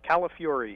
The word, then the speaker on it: Calafiore: It's Calafiore. It sounds like California, but you get angry at the end. Fury.